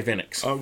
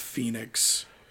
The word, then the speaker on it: Fénix: You're taking on Rey Fénix. – Rey Fénix. Now. – Is it Phoenix or Fénix? – I think they pronounce it Phoenix.